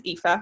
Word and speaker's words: Aoife: So my name is Aoife. We'll start with that because that confuses everyone with the spelling. Yeah. Irish name pronounced like FIFA without the first F, I always say, because here in Australia, nobody can pronounce it.